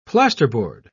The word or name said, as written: plasterboard